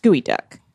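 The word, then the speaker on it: geoduck: It's derived from a Lushootseed word, which means to dig deep, and is pronounced gooey duck.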